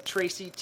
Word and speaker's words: Tracy: Instagram, Twitter, at Tracy Tubera. Tracy, T-R-A-C-Y, no E.